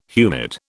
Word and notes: humid: British English

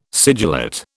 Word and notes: sigillate: British English

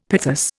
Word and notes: pithos: Indian

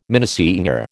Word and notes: minnesinger: American English

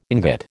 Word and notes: ingot: American English

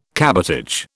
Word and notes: cabotage: British English

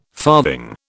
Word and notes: farthing: British English

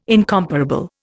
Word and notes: incomparable: Indian

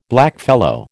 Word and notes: blackfellow: American English

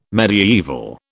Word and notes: mediaeval: British English

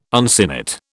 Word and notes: uncinate: British English

